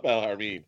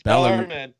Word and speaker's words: Bellarmine: Okay, so I was corrected on my pronunciation. – Yeah, I seen that. Yes. I was like, oh, it's not Bellarmine. – Yes. – It's Bellarmine.